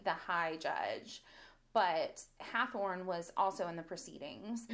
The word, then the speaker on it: Hathorne: I'm like Hathorne. It's not Hawthorne.